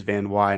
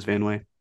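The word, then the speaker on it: Van Wey: But it's VANWEY, but you pronounce it as Van Wy. And I thought the whole time it was Van Way.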